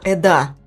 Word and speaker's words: Эда: А, Эда Ильдис, и почему мы не сказали Эда? Мы знаем, что в Турции ставится ударение всегда во всех именах на последний слог, поэтому она Эда.